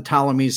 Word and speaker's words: Ptolemies: Okay, these are the Ptolemies spelled with a P in the front, right? – Yeah. – Right. Okay, so this was like pterodactyl, right? Or Ptarmigan. So the Ptolemies were sorry.